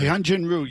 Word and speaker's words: Hyunjin Ryu: Is that how you pronounce the name? – Yeah, Hyunjin Ryu.